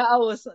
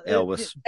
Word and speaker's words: Elwes: That Cary Elwes. I don't know how to say his name. – Yeah, Elwes. – Elwes.